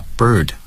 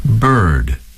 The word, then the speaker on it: bird: 所以这一点呢，我想 会为大家读一下 bird 和 hurt 这两个单词，因为它能更好的展示这两个单词的在美式英语的读音是怎么样。 – Bird, bird, hurt, hurt. – Some speakers of British English do not sound the R in this word.